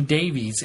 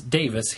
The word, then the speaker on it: Davies: And on another pronunciation note, the surname Davies in the UK is generally pronounced the same as Davis here in the States. So Russell T. Davis pronounces his name Davis.